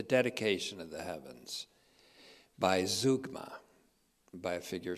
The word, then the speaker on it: zeugma: since we're dealing with Z-O-O sounds, zeugma, but it's spelled this way, zeugma, Z-E-U-G-M-A, but it's pronounced zeugma.